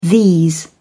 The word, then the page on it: these: British English